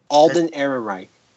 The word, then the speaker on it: Alden Ehrenreich: Einhard or Alden Ehrenreich.